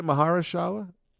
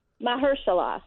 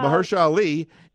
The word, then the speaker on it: Mahershala: How do you say that? Maharshala? Marshala? – Mahershala. – Mahershala. – Mahershala. – Mahershala Ali.